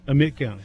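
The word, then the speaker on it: Amite: Now, is it, now how do you pronounce this? Is it Amit County? – Amit County, yeah.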